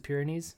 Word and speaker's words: Pyrenees: He found God and disappeared to the Pyrenees. I'm pronouncing that right, right? It's the Pyrenees? – Yeah.